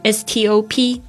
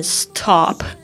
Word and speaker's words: stop: 这个路边停车这个停这个标志 s t o p 怎么念呢？stop，希望不要念成 stop，这样很奇怪。